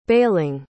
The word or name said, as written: baling